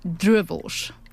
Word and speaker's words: droewors: That is droewors. – Okay. – Okay, that's with the Afrikaans way of pronouncing it.